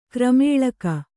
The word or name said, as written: ಗಿರಿಮಲ್ಲಿಕೆ